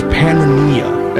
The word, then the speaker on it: Pannonia: It's Pannonia, as it's pronounced back then, and he grew up in a Roman Empire that was crumbling in slow motion.